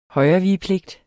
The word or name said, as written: højrevigepligt